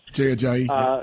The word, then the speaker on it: Ajayi: They got Jay Ajayi, I believe is how you say his name. – Jay Ajayi.